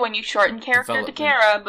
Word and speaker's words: Chara: Chara, how do you pronounce the character from Undertale? – It's Kara.